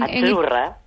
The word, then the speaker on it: Azzurra: Sí, como porque pizza, la Z no es la Z como decimos en catalán tan sonora, no. – Ah, es azzurra. – Muy bonito, muy bonito. – Es como pizza.